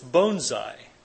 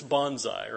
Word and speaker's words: bonsai: If you want to say it right, it's bonsai. But if you want to say it like everybody else says it, it's bonsai, right?